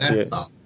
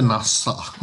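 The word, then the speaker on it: Nassau: NASA, inte NASA. – NASA, ja. Uttalsfrågor är ingen styrka.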